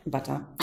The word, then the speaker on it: bata: Do I pronounce it? – Bata.